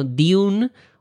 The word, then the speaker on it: Dune: sino Dune o Dune, pues de lo que estamos hablando, por si acaso hay alguna duda.